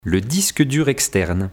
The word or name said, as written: Le disque dur externe